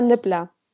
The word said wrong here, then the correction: Niffler: Nee, die heißen Niffler.